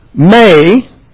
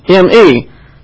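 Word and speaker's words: may (μή): Now you see, this is, in the Greek, that's pronounced may. That's a mu and an eta. And it's pronounced may. But over here in the English letters for this is me, M-E. But it's not pronounced me, it's pronounced may. This is a long A.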